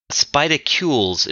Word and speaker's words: Spidicules: I've been mispronouncing this expansion for years now. I feel fairly silly about it. Spidaculs is what this thing is called.